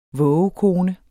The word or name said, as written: vågekone